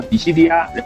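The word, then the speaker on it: ディシディア: 然后那个我说一下这款游戏的日语读音吧，这款游戏的日语读音叫迪西迪亚，然后有兴趣的朋友可以去查一下。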